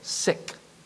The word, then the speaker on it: Sikh: But it's actually pronounced Sikh. Like someone is ill, sick. And so it's there's a spelling, S-I-K-H. It's pronounced sick, the Sikh religion.